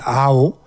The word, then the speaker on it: Aho: Alors, c'est un compositeur finlandais que nous allons écouter, Monsieur Kalevi Aho. Aho, ça s'écrit A-H-O, c'est très simple, hein, pour une fois, un nom finlandais qu'on peut prononcer.